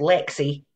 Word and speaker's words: Leagsaidh: And my Scot in California is called Lexi. And that's spelled Leagsaidh, which is Katrina with an O dialed up to 11.